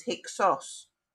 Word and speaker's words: Hyksos: And over time, that became, uh, you know, when the Greeks took over Egypt, looking back at Egypt's history, they pronounced Heka Hasut as Hyksos with the Greek ending.